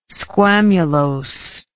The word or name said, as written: squamulose